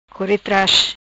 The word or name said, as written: Core Tras